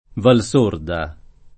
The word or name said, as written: Valsorda